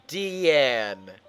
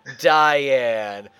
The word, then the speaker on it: Diane: It's Deanne, not Diane.